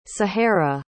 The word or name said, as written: sahara